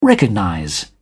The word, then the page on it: recognize: British English